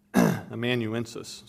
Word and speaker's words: amanuensis: Paul's Amansus, his secretary, amanuensis, sorry, I can never pronounce that word.